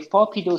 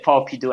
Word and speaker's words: Fopidoo: So the correct pronunciation is Fopidoo. – That's correct. Fopidoo, not Fopidoo, but Fopidoo. – That's correct. It's like play on Scooby-Doo.